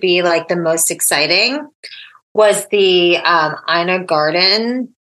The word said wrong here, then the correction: Ina Garten: Garten. How do you mm-hmm. say her name? Ina Garten.